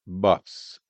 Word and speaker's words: Buhs: Bonnie Buffs, and that is spelled B-U-H-S, Gibson City.